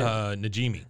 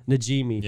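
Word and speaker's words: Najimy: I don't know how to pronounce her last name, but the lady that does Peggy's Namji, is that how you say it? – Najimi. – Najimi.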